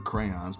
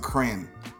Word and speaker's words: crayon: I mean, I could go on about the colors of the crayons because those are incredible. And I hate when people pronounce it crayon. Shut up, it's crayon. It's an O in there.